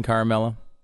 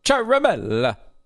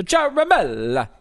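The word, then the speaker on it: Ciaramella: A lot of people are saying Caramella. It's Charamella. – Charamella. – Charamella. Because okay, it's Italian. – It. Yeah, okay. – And the emphasis should be on the third syllable. So Charamella.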